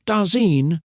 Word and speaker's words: Dasein: Heidegger created a special word for human existence, Dasein, pronounced Dasein, which means being there or presence.